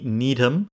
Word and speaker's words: Needham: Okay, Jimmy Needham. Needham is spelled N-E-E-D-H-A-M. Like you need ham, right?